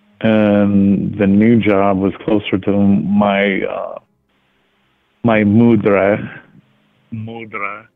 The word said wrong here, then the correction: madre: Am I saying that right? Madre. – Madre, yeah.